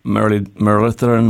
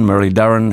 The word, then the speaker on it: Muralitharan: And the other guy, of course, is ended up being the greatest wicket taker of all time, Mathai Muralitharan, Murali Dharan, how many ways you can pronounce it, but Murali anyway, to his mates.